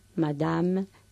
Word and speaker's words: madame: Sí, por ejemplo, la E final en francés, la E final de palabra que no lleva tilde, no se pronuncia. Es lo que llamamos E caduca no pronunciada. An, madame, quatre, son, valise. Esas E finales, aunque ya haya una S a continuación, no llevan tilde, no se pronuncian.